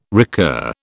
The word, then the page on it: recur: British English